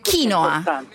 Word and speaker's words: quinoa: Ciao, diciamo subito che l'accento va sulla I. – Allora è quinoa. – Non sulla O come si è abituati in Italia.